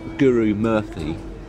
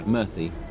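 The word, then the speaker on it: Guru-Murthy: And then Krishnan Gurumurthy, is that how you pronounce it? – Yeah, T-H rather than P-H. – Yeah, Murthy.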